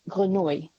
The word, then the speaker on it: Grenouille: If Jean-Baptiste Grenu, was that a good pronunciation? – Is it G-R-E-N-O-U-I-L-L-E? I think if it's the French word, it's Grenouille. – Grenouille, thank you.